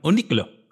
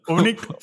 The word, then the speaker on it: Uniqlo: No sé cómo lo pronuncio distinto tú porque estás usando un acento de Málaga. – Seguro. – O algo así. – Seguro, pero lo diría, Uniqlo. – Uniqlo. Así.